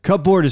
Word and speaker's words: cupboard: Cupboard is how it's pronounced.